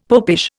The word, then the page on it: popish: Indian